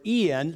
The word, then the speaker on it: en: And the secret is in Christ. I can do all things. Your Bible say through. The better word is in. It's actually the Greek word, en. It's pronounced in.